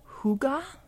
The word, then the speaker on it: hygge: I'm going to uh, tenuously try to pronounce it. Um, it's called hygge and hygge, uh, which means bringing happiness to the home through coziness and comfort.